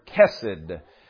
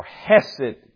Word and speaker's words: chesed: It speaks of the word chesed or hesed can be pronounced that way.